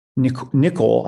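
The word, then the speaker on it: Nicoll: Nicole, I believe is how you pronounce his name, Maurice Nicole, yes, who you are honoring in this series, might call this the observing eye, a term which I suspect you're familiar with.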